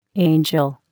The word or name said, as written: angel